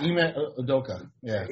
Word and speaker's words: Ime Udoka: Steve Kerr, 6 NBA Finals as a coach versus, how do you say his first name? – Ime. – Udoka. – Ime Udoka, yeah.